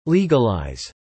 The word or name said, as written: legalize